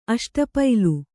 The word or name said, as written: ಅಷ್ಟಪೈಲು